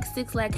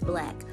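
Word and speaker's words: 6LACK: You know, when is, I think 6 Lack was in the category with him, but I don't think 6 Lack has, I have to stop calling that boy 6 Lack, because that's not how you say his name. It's Black. I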